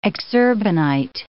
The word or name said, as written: exurbanite